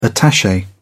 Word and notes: attache: British English

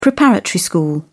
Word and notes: preparatory school: British English

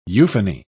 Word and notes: euphony: British English

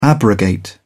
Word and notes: abrogate: British English

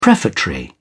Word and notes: prefatory: British English

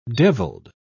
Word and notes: deviled: British English

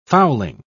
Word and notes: fowling: British English

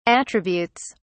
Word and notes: attributes: British English

